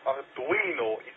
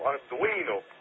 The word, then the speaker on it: Arduino: Ja, Arduino moet je zo uitspreken, hè. Het is Arduino, Italiano, Arduino.